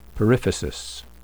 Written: periphysis

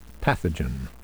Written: pathogen

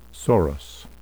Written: sorus

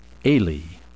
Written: alae

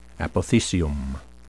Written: apothecium

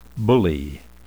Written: bullae

